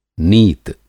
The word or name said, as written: नीत